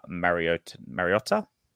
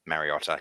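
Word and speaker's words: Mariota: And then on the bench, Tom Starling, Emre Gula, Matt Frawley, and Atta Mariotta. – Mariotta, yep. – That's yeah, I went very Italian then with that pronunciation.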